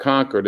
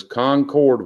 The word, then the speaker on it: Concord: Now, what was it like growing up in Concord? You said Concord. – That's correct. Not Concord. It is Concord when you're in the South.